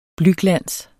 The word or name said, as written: blyglans